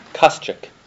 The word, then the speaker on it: Kosciuch: My name is spelled Carl, K-A-R-L. My last name is Kostchek, spelled K-O-S-C-I-U-C-H. – And one more time, please pronounce it for me. – Kostchek.